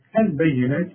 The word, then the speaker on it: البينات: مريم البينات، البينات كلها مرققه، وعندك فوق النون الف علشان تقول البينات وتكتبها كده زي ما انت شايفه في المصحف، لكن تنطقها بالألف كاملة.